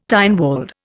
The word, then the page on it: Tynwald: Indian